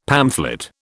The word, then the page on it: pamphlet: British English